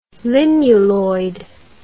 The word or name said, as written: limuloid